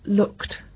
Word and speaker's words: looked: Y después de ciertos sonidos consonánticos, el sonido consonántico sordo, I look, se pronuncia en el pasado o en el pretérito, añadiendo ed, se pronuncia looked. I look. I looked.